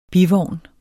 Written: bivogn

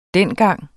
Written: dengang

